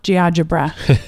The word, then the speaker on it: GeoGebra: It's like algebra, but Geogebra.